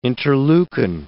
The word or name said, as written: interleukin